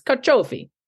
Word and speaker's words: carciofi: C-I-O-F-I. I'm not a speaker of Italian. – What is it? I Googled it. I listened to videos and I had an Italian tell me it's carciofi.